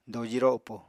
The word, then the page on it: doji ropo: apatani